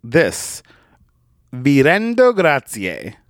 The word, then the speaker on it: grazie: Gratitudine is spelled G-R-A-T-I-T-U-D-I-N-E. Gratitudine. Gratitudine. And grazie is G-R-A-Z-I-E. Grazie. Thank you.